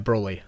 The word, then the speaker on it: Broly: Yeah, Broly.